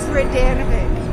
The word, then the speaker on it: Sredanovic: Gail Sredanovic.